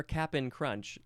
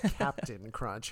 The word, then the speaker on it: Cap'n Crunch: That's why your Cap'n Crunch is not sharp and crusty. – And that was good pronunciation. I realized I said Captain Crunch.